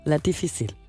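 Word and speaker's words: La Difícil: og det hedder La Difficile. – Ja. Var det rigtigt udtalt? Ja.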